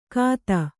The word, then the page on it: ಕಾತ: kāta